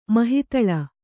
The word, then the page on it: ಮಹಾತಳ: Kannada